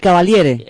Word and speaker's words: Cavaliere: El Cavalieri, ¿cómo se dice? – El Cavaliere. – Exacto.